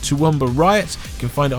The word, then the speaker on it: Toowoomba: And I hate to say it, the only reason I know how to pronounce Toowoomba is because of Kathleen Jenner Overdrive.